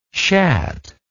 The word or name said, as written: sceat